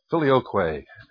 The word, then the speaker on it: Filioque: Oh, we're just doing the Filioque. Well, which, how do you pronounce that? – Filioque.